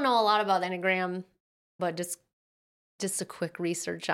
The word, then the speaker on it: Enneagram: I don't know why she just, oh yeah, she jumps into making a point about the, how do you say it, enneagram? – Enneagram is what I would say.